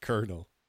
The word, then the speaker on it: colonel: How the f- is colonel pronounced colonel? – Yeah, that's actually a good. That's a good question. It's kind of like um, like like the the car company Lincoln. Like it's not spelled like that. It's Lincoln. It, but it's not even close. Like there's no R in colonel.